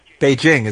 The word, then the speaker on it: Beijing: Beijing, by the way. That's how you say it. They call me Beijing. – Beijing is the correct pronunciation.